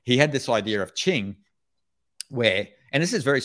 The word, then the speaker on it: Qing: In Taoism, it's in Chinese, it's called Qing. So in Pinyin Romanization, you'd spell that Q-I-N-G.